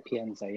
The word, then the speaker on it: Pienza: That's right, Pienza.